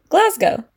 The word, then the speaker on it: Glasgow: How did you say it? – Glasgow. – Yeah, okay, no, you say it right.